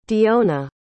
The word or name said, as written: diona